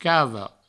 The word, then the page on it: cava: provençal; cavo